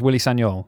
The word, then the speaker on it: Willy Sagnol: or as it's correctly pronounced, Willy Sagnol.